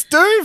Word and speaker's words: Dune: It's literally spelled D-U-N-E. – Not J-U-N-E. – June or Dune, I guess. It's Dune, for fuck's sake. – Dune. – It's D-U-N-E.